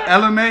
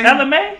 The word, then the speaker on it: Ella Mae: Is it Ally Mae? – I think that's how you say it. – Is that how you say it? – Ella Mae. – Ella Mae.